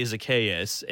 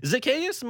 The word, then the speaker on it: Zaccheaus: And I would think to a certain degree it leaves Olamide Zaccheaus. And whenever I say his name, I think of the character from the Planet of the Apes, like the Dr. Zaius, like Dr. Zaius, Dr. Zaius, you know, that song.